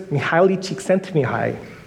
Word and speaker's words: Csikszentmihalyi: Mihaly Csikszentmihalyi.